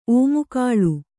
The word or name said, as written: ಓಮುಕಾಳು